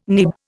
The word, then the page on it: nib: Indian